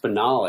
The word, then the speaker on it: banal: And then finds Winston, like, cooking or something really banal. And he's like, oh, look who's awake. Let me introduce you to Bubba. Like, that's it's pronounced banal, I think. – Is it banal? Yeah, you're probably right.